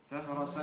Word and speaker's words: فهرس: فهرسا مؤقتا بالكسر أحسن، الفهرس تنطق فهرس بالكسر، فهرس كلام،